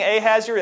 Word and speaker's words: Ahasuerus: You remember Haman in Esther chapter 3 was promoted by King Ahasuerus. Is that right, Priscilla? Did I say it right? Yes, okay. King Ahasuerus, that's an inside joke.